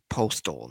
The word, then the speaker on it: Postl: Dylan Mark Postal, not Postal like the, but his last name is spelled P-O-S-T-L.